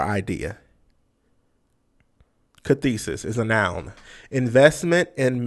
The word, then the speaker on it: cathexis: The first word is cathesis. – Carthesis or Cathesis is spelled C A T H E X I S.